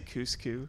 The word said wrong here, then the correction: Ceaușescu: Cuscu? – Ceaușescu? – Ceaușescu. Okay, Jesus. We are never going to have a Romanian on this podcast. I refuse to have my pronunciation corrected.